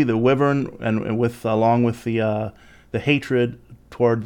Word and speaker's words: wyvern: Now, also, the Leeds family had a family crest, which was basically a wyvern, which is, you know, wyvern, wyvern, both are correct pronunciations.